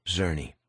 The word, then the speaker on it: Czerny: Thank you for coming in, Mr. Czerny. – Czerny. – You say it, Czerny. C-Z-E-R-N-Y. The C is silent.